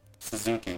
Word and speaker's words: Suzuki: So, like, Suzuki training, is that how you pronounce it, Suzuki? – Suzuki, yeah.